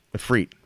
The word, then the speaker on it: Efreet: Frentic Efreet. Am I saying that right? – The Efreet? – Efreet. There you go.